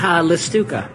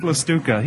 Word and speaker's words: Lestewka: Lestewka. – Patrick Lestewka.